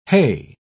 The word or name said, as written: hay